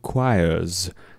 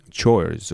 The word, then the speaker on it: choir: Many students say chores or choirs or whatever. No, it's choir, even though the spelling is C-H-O-I-R.